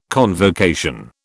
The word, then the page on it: convocation: British English